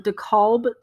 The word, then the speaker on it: DeKalb: Taylor Morton, who is 27, she was stopped at the side of the road near DeKalb, Texas.